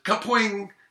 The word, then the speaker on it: Kapwing: Spelled K-A-P-W-I-N-G. – K-A-P-W-I-M-N-G. Cap yeah. – Wing. – Yeah, except they pronounce it Cap-Wing.